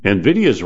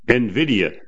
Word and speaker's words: NVIDIA: learn how to say its name. NVIDIA, not NVIDIA.